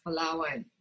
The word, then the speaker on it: Palawan: is it Palawan? – Yes, Palawan.